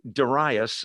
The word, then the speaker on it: Darius: Daniel 9 verse 1 In the first year of Darius, or Darius is how most people pronounce it,